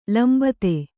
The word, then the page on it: ಲಂಬತೆ: lambate